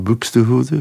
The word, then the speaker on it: Buxtehude: En ik heb die LP van Alfred Deller zingt op de A-kant muziek van Buxtehude. Ik weet niet hoe je het moet uitspreken. – Ja, dat is helemaal juist.